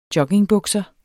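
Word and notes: joggingbukser: Danish

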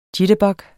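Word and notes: jitterbug: Danish

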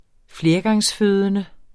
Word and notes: flergangsfødende: ˈfleɐ̯gɑŋsˌføːðənə; -ˌføˀðənə

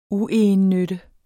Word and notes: uegennytte: [ ˈuˌejənˌnødə ]; [ ˈuˌɑjənˌnødə ]

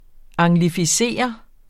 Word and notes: anglificere: ɑŋlifiˈseˀʌ; ɑŋgli-

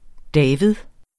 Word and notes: David: ˈdæːvið

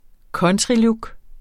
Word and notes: countrylook: ˈkʌntʁiˌlug; ˈkʌntɹi-

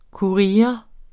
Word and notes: courier: kuˈʁiːɐ̯; ˈkuɹiʌ; kuɐ̯ˈje

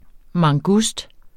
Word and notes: mangust: mɑŋˈgusd

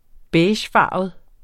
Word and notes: beigefarvet: -ˌfɑˀvəð; -ˌfɑːvəð

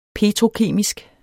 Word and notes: petrokemisk: ˈpeːtʁoˌkeˀmisg

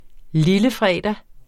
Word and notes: lillefredag: ˈliləˌfʁεˀda

